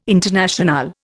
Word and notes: Internationale: Indian

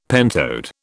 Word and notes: pentode: British English

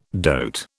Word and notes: dote: British English

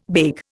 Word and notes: beg: Indian

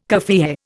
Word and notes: kaffiyeh: Indian

